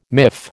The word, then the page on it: miff: American English